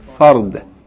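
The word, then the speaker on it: Fard (فرد): Sometime when you pronounce the word, Farad, this is Del. And that word, obligatory, is bad. Farb. This is dal. Fard. Da. It's a light sound.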